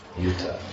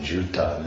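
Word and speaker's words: yutha: But if you say Juta, then it becomes shoes or whatever. You know, Juta. So it's Y long U, Yuta.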